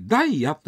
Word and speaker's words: 大谷: 大谷と書いてダイヤと読む川があるんですね、うん、ダイヤ川、